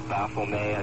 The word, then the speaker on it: Baphomet: They represent with Baphomet, I believe it's pronounced. – That is correct.